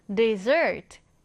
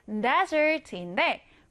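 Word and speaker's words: dessert: 그런데 이 단어를 한번 보시면 desert, desert. S가 몇개 있죠? 두개 있습니다. 자, 발음도 좀 다릅니다. 사막은 desert인데 후식이나 디저트라는 의미의 단어는요. 뒤에 강세가 들어가서 desert, desert. 좀 다르죠?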